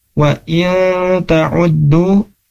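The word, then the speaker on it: Wa in ta'uddu (وَإِن تَعُدُّوا): Terus kemudian di ayat ke-18-nya, wa in ta'uddu, huruf nun ketemu dengan huruf ta, nah ini disebut dengan ikhfa ya, jadi bacanya seperti apa, nah bacanya harus digunnahkan, dimasukkan ke hidung.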